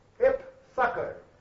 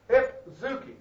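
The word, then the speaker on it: eupsucho: When Paul says that he wanted to be of good comfort, he uses the word in the Greek which is pronounced epsuko, which means well-souled. It comes from the word zuki, which is the word for soul. Epzuki, or epsuko, whatever you like to pronounce it.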